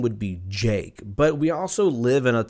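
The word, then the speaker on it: JVKE: His name is, it's Jake, but it's spelt like Javiki, and if you Google it, it's J-V-K-E.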